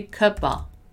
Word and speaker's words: cup of tea: When we say cup of tea a little faster, we're gonna once again turn that of, the word of, into a. So we're gonna say cuppa, cup of tea, cup of tea, cup of tea, cup of tea.